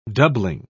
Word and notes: doubling: British English

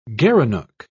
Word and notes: gerenuk: British English

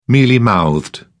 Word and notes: mealy-mouthed: British English

